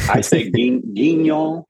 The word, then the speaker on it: Guignol: I mean, like, is it Grand Guignol? Is that how it's pronounced? – Yeah, Guignol or Guignol. I say guignol.